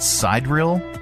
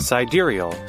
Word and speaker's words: sidereal: And some simple math will show you that over 4 years, the difference between the calendar years and the, what is that, sidereal? – Okay. – Let me get a pronunciation key for this. Here, listen. – Sidereal.